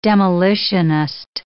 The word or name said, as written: demolitionist